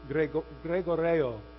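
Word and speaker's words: gregoreo (γρηγορέω): That's the Greek word gregoreo. It's like Gregorio. Greg, the name Greg, and then Oreo. Gregoreo, right? So that's the Greek word gregoreo, I think is how you say it, right?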